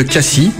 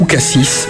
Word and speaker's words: Cassis: Figurant parmi les toutes premières appellations d'origine contrôlée en 1936, avec Sauternes et Châteauneuf-du-Pape, découvrons ensemble un vignoble riche de qualité, le vignoble de Cassis ou Cassis, selon votre prononciation.